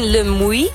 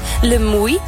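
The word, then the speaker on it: Le Muy: En we zijn nu op een fantastische, hele grote camping in Le Mouy. Zo spreek je dat uit, ja, ja. – Le Mouy.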